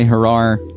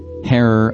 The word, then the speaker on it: Herer: Some folks say Herar. Herer, I believe, is what it's pronounced as. H-E-R-E-R.